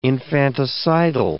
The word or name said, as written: infanticidal